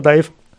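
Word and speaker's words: DIIV: Um aber Überschneidung mit dem belgischen Industrial-Projekt Dive, also genauso geschrieben, zu vermeiden, änderten sie sich ihre Schreibweise kurzerhand in Dive, Dive. Ausgesprochen bleibt es weiter Dive.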